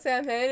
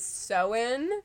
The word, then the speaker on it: Samhain: It wasn't until this very point when I figured out that I was pronouncing Samhain incorrectly. It is not Samhain, it is Samhain.